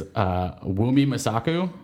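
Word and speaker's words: Wunmi Mosaku: Well, so it is Wonmi Masaku as Rial, Shope Derisu as Bol. – So you pronounce it with an H in there, even though there's no H?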